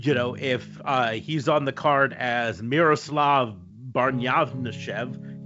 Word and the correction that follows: Barnyashev: geez, I apologize for butchering that name. Barnyashev? Miroslav Barnyashev.